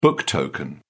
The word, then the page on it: book token: British English